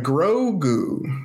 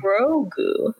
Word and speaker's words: Grogu: I want to say Goku because ever since Rowan said that, it's very similar, but yeah, it's like Grogu. – Grogu.